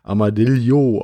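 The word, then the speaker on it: Armadillo: Dann fangen wir an mit einer kleinen Schachtel, einem kombinierten Karten- und Würfelspiel, und zwar Amadillo, oder wie ich inzwischen gelernt habe, wahrscheinlich sogar Amadillo ausgesprochen. Das ist ja bei dem Doppel-L immer so ein bisschen anders, wenn man dann in die ähm, ja, spanischen Sprachen oder portugiesischen Sprachen geht.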